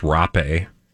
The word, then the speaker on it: rape: I hope that's pronounced rape. – Yes.